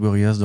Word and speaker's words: Gorillaz: Apparemment, il faut dire Gorillaz et pas Gorillaz. Ouais, mais nous, on est en France, donc euh, on, a notre prononciation euh, à la française.